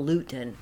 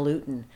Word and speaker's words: Lewton: When I heard it, I would always picture that it was spelled L-U-T-E-N. – Mm-hmm. – But it's spelled L-E-W-T-O-N.